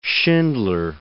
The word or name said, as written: Schindler